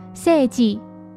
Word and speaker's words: 成実: 医生的名字就叫做诚实，日语读作拿鲁咪，而麻生诚实的诚实日语念作 seiji。